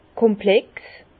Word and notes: komplex: Bas-Rhin; Herrlisheim

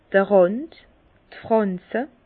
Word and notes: d'r Rànd, d' Frànze: Bas-Rhin; Herrlisheim

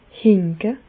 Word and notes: hinke: Bas Rhin; Reichshoffen

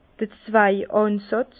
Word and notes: d'r Zweigànsàtz: Bas-Rhin; Herrlisheim

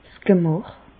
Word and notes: 's Gemäch: Bas-Rhin; Reichshoffen

